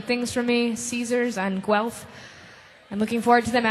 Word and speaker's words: Guelph: I'll be with the Tanyas again tomorrow night in Guelph, I believe is how you say it. Is that correct? Guelph? – Yes. – I'm looking forward to it.